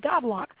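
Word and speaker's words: Goudelock: B-A-R-B-A-R-A, and my last name is spelled Godlock. That's G-O-U-D, like in David, E-L-O-C-K.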